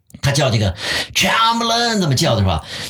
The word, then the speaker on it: Chamberlain: 张伯伦把它读成枪伯伦，嗯，Chamberlain 这么来读，那么他就是 Chamberlain 就这么读出来了，Chamberlain。